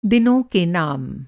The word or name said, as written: दिन